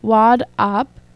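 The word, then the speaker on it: WAWD AHP: Gerard brings this aesthetic to his new short film, Wad Op. It's spelled W-A-W-D space capital A-H-P, which both vividly depicts the creative process and features a decapitated rapper having sex with his own severed neck.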